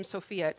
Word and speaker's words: Sophia: Now, the crazy thing is that word wisdom is Sophia, our name Sophia, that's how you pronounce it.